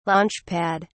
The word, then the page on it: launchpad: British English